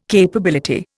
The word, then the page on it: capability: Indian